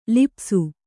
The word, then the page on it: ಲಿಪಿಸು: lipisu